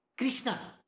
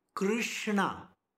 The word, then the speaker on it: Krishna: Krishna. Krishna. Emphasis is not there at all. Krishna. Krish. Krr. Did you get it?